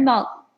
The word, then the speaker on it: Maltin: Your father is the very famous movie critic, Leonard Maltin. Yep, I said it right.